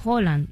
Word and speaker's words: Haaland: Es Haaland.